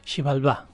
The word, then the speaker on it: Xibalba: ¿Cómo se dice? ¿Xibalba o Xibalba? – Xibalba.